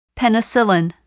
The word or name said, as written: penicillin